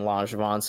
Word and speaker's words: Langevin: I was going to say Langevin, but Langevin is way cooler sounding, and I hope that's actually the right pronunciation because, yeah, definite. Like at least 60 on the name. – 100%. I cheated a little bit. I watched like an ESPN Plus stream and they were saying Langevin, so I didn't.